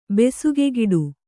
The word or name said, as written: ಬೆಸುಗೆಗಿಡು